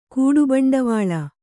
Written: ಕೂಡುಬಂಡವಾಳ

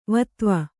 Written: ವಿಧವೆ

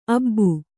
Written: ಅಬ್ಬಿ

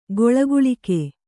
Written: ಗೊಳಗುಳಿಕೆ

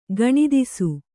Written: ಗಣಿತಿಸು

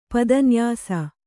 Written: ಪದನ್ಯಾಸ

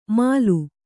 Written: ಮಾಲು

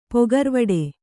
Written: ಪೊಗರ್ವಡೆ

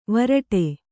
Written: ವಿರಾಟ

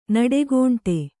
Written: ನಡೆಗೋಂಟೆ

